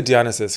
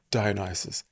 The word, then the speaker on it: Dionysus: So I say Dionysus because it feels more elegant to me, but I think the Brits say Dionysus.